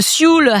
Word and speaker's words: Sioule: You have the Sioule Valley, which I had never heard of either. I can see your face. You haven't heard of it. – I don't even know how you spell that. – S-I-O-U-L-E.